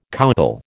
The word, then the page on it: coucal: American English